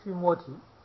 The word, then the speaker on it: 须摩提: 极乐者，凡于须摩提啊，极乐世界，这是我们从它的意思翻过来的。梵文的读音呢，发音叫须摩提，